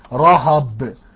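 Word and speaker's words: رهب (רהב): الكلمة العبرية رهب بتنطق رهب، وهي لا تعني إلا الوحش المائي رهب، إلا الوحش المائي رهب.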